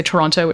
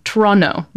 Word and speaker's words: Toronto: And I have been out of Toronto long enough that I now sometimes say Toronto, which is really awful. But anyways, I was very happy to hear her pronouncing it as it should be. Toronto. No second T pronounced.